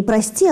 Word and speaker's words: прости: Некоторые ребята, они говорили мне, они думают, что это такое слово «прости», которое произносится с таким странным ударением. Не «прости», а «прости». Но на самом деле, конечно же, нет. «Прости» это однокоренное слово со словом «простирать» или «простираться», то есть «выпрямляться».